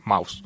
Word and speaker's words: Maus: Se escribe Mouse, no Mouse como Mickey Mouse, sino alemán como Mouse, entonces M-A-U-S.